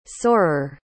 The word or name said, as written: sorer